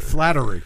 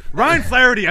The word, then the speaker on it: Flaherty: He pronounced it wrong, though. He said Flattery. – Ryan Flaherty. I know how to say the name, all right? – We've gone over his pronunciations.